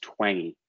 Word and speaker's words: Twenge: There's a great book by a professor named Gene, I think his name is pronounced Twenge, T-W-E-N-G-E, called iGen.